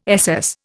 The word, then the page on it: SS: Indian